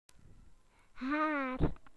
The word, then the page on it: hair: British English